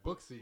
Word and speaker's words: Booksy: How do you say this? Yeah, Booksy. – Booksy. Download that.